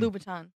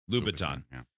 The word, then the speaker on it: Louboutin: Oh, Christian Louboutin. – Louboutin.